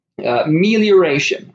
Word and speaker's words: melioration: The spelling is M-E-L-I-O-R-A-T-I-O-N. And as far as I can tell from what research online I've done, telling various different software pieces to pronounce it for me, I believe it is pronounced melioration.